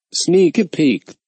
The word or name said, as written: sneak a peek